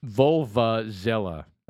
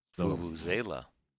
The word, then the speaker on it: vuvuzela: Vulvazela. – How do you pronounce that? – Vulvazela. – Oh, it looks like vulva. – Vulva? – Vulvazela. – Vuvuzela.